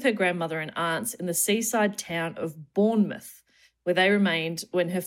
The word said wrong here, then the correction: Bournemouth: I think English people will be annoyed by that pronunciation. It's Bournemouth. we'll get tweets if we don't correct it. – Yeah, thank you for Bournemouth.